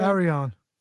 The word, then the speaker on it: Arion: Tony took Jim Herd, Jimmy Valiant, Mark Lewin, Yukiko Tome, Johnny Rods, Eddie Sharkey, Abby, Spiros Aron. Did I say it right? – Arion.